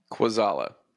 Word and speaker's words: Kwaczala: Kwaczala.